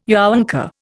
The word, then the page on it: younker: Indian